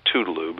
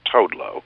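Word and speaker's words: Toodle-Oo: Most people say Toodaloo, but he pronounced it Toadlo.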